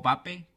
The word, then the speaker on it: Mbappé: Mbappé o Pape, como según dicen que se pronuncia, intratable.